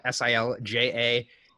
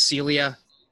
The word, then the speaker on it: Silja: He wrote in to say the name that we kept saying Silja S I L J A is not pronounced as Silja. It is pronounced as Celia.